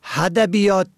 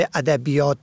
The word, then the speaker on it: ادبیات: و ادبیات را که با الف شروع می شود با ه هدبیات تلفظ می کنند.